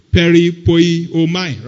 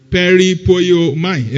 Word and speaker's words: peripoieomai: I think it's P-E-R-I. P-E-R-I-P-O-I-E-O-M-A-I. Try and pronounce it for me. P-E-R-I-P-O-I-E-O-M-A-I. Peripoiomai, right? You didn't write it. Peripoiomai.